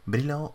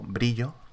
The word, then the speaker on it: Brillo: Brillo o Brillo, tal como se escribe en castellano,